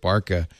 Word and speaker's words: BRCA: Wouldn't you want to know if the BRCA, I've been told you don't pronounce that BRCA, you pronounce it BARCA.